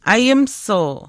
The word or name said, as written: aayimisiu